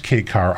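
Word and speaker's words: kei car: And it's pronounced K-Car, I believe. – Yes, it is spelled K-E-I. Uh-huh. It comes from the Japanese translation.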